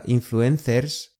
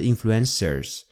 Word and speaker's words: influencers: 二， No sigo a muchos influencers， No sigo a muchos influencers，我不怎么关注网红。请注意那个 influencers 就是网红的西班牙语虽然写的跟英语一样的，但是发音会有点不一样，不是 influencers，而是 influencers，发音就是很有西班牙的味道，西班牙语的味道。